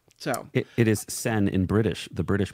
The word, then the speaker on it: Seine: The big show with the opening ceremony on the River Seine did I say it right? River Sen? I think so. – Sen. Uh, July 26 marks the first time an opening ceremony will be held outside of a usual stadium setting. So that's kind of a big deal that they're doing that. Sane. Sane. Sane. Sane. River Sane. That's the American um, pronunciation.